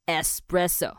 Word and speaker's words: espresso: espresso 哈，它其实是没有 x 的，真的不要再执着了，OK？我有个意大利朋友，我请他来念给你们听。